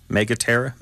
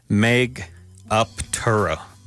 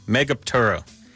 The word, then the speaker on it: Megaptera: Yeah, how do I pronounce Megaterra, though? Who knows how it's pronounced? Text me. Tell me how that's pronounced. Where is it? Megaptera. Megaterra? Megaptera? My wife's showing me the instructions here. Megaptera. Megaptera. Okay, humpback whale, thanks for making my life difficult.